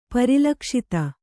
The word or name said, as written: ಪರಿಲಕ್ಷಿತ